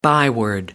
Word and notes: byword: American English

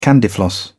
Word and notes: candyfloss: British English

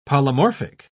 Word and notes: polymorphic: British English

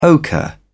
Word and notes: ochre: British English

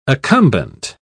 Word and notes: accumbent: British English

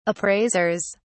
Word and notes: appraisers: British English

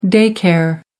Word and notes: day care: American English

